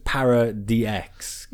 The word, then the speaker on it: Para-DX: It's pronounced Paradox, it's Para-DX. Kind of like the deluxe things they have with the toys.